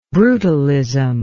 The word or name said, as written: brutalism